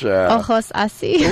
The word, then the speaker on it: Ojos así: Is it Ojos? – Eyes Like Yours. – Eyes Like Yours. So I don't know. Are you good in Spanish? – Ojos así.